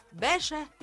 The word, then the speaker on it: باشا: باشا يا إخوانا كلمة تركية تنطق مفخمة مش مرققة. باشا بي تقيلة يعني تقيلة شوية، بي باشا معناها ولاية أو وزارة.